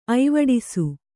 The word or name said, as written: ಐವಡಿಸು